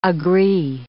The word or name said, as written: agree